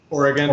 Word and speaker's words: Oregon: I think some would disagree with emphasizing the gun part, but let's keep the politics. – So how do you pronounce the state? – Oregon.